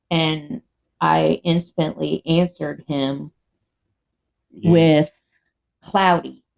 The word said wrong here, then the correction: Claude: And which that's another Claude. – That's how it's pronounced, but that's one thing that you do.